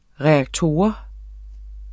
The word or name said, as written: reaktor